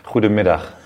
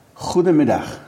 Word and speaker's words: Goedemiddag: How could I say good afternoon properly in Dutch? – Goedemiddag. – Goedemiddag. – Exactly, very good. – Thank you. Well, listen, one tries. – Pronunciation is very good.